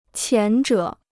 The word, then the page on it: 前者: qián zhě